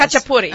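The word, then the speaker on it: khachapuri: Oh, see, I can say a kachapuri. – Yes. – Kachapuri. – Uh-huh. – Oh, I said it right. – You did it good.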